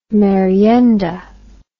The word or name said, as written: merienda